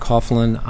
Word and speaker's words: Coughlin: For the record, my name is Patrick Coughlin. I'm a counsel for BP, and my last name is spelled C-O-U-G-H-L-I-N.